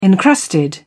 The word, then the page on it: encrusted: British English